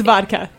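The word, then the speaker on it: wódka: W in Polish is pronounced as a V. It is pronounced vodka.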